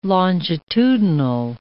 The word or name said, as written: longitudinal